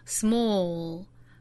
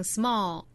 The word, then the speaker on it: small: 在发音的时候呢， dark l 的发音方法和 light l 是相似的，舌尖去抵上齿龈之后用喉咙发出 l 的发音， small， small， bowl， bowl， Gold, gold. 很多同学会把舌侧音直接略掉发成 small, bow, gold. 舌侧音直接略掉是不对的，一定要注意去摆这样的一个动作，就是舌尖去够上齿龈，然后发 l 的发音。